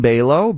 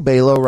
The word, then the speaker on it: Balogh: My name's Coleman Balogh. Balogh rhymes with halo, like the Beyonce song. C-O-L-E. Coleman Balogh.